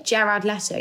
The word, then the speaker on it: Jared Leto: I don't know, Jared Leto. Am I saying that right? – Jared Leto, yeah.